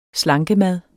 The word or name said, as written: slankemad